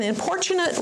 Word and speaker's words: importunate: Importune, an importunate rhymes with misfortunate, but it is the opposite of misfortunate.